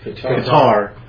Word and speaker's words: Qatar: What they're doing is they're introducing Disney Plus into the following countries: Algeria, Egypt, Libya, Morocco, Oman, Palestinian Authority, the Qatar. Well, my pronunciation. – Qatar.